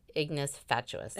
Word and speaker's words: ignis fatuus: I did look up how to pronounce it, and that's how you pronounce it, ignis fatuus.